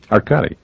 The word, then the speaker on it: Arkady: And am I mispronouncing Arkady? – Arkady. – Arkady. – Arkady Rinko. – Arkady. And I'm saying Arkady. All right, Arkady.